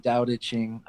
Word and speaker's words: Tao Te Ching: You're happy to dive in them a little bit, but Conversations with God by Neil Donald Walsh, and I'm not going to Tao Te Ching by Lu Zhao. I know I butchered that, but you can say it for me if you'd like. – Yeah, no worries. The Tao Te Ching.